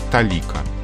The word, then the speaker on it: толика: То есть в каком-то смысле она связана со словом «количество», и поэтому ударение там падает на тот же слог «количество талика». Малая талика.